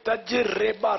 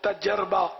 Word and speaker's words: تجربہ: بدگمانی، تجربہ اور اندازہ، یہ تجربہ را کے نیچے زیر تجربہ، صحیح تلفظ. ہم عام طور پر تجربہ یا تجربہ ہمارے یہاں بولا جاتا ہے، صحیح تلفظ تجربہ.